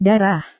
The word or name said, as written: darah